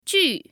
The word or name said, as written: ju4